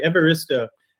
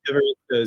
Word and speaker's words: Evaristo: Yeah, so it was very, very close, actually. Evaristo. – All right. – Evaristo is usually how I introduce myself.